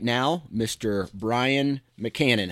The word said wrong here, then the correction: McCammon: Wait, I think I said your name wrong, right? Is it McCann? – Mc- you did, but that's all right. I'll let it slide. Tell me. Okay, so it's Brian what? We got to say it right. It's McCammon. – McCammon. With M's in the middle. – Okay, yep, that's McCammon. right.